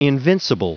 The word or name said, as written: invincible